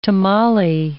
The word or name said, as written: tamale